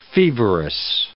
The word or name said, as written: feverous